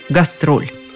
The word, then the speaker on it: гастроль: Поэтому в русских словарях оно было сначала с немецким ударением – «гастроль» от «гаст» – «гость», «роль», роль. И только потом постепенно ударение сместилось на второй слог – гастроль.